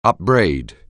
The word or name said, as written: upbraid